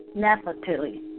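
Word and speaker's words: Naphtali: We have entered the month of Nefetai. Nephtali. Yes, excuse my pronunciation.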